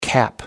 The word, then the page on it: cap: American English